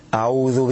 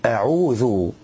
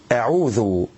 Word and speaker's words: أعوذ: بعض الناس إذا نطقوا العين تراهم يمرون عليها مرورا كأنها أشبه بالهاء، أعوذ بالله من الشيطان الرجيم. والصواب أن يبين القارئ نصوعها، يعني وضوحها وبيانها، ويقول أعوذ دون إفراط، كأن يقول أعوذ أو تفريط أعوذ. والصواب أعوذ. طيب الخطأ الثالث معانا عدم إخراج الذال من مخرجها الصحيح وإبدالها زايا، بدل ما يقول أعوذ يقول أعوذ بحرف الزاي، ينطقها بالزاي.